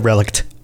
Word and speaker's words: relict: That's why they make the relict oil. – Relict. Yeah, it's relict. Hard to say. – Yeah. – You don't really put an emphasis on the T there.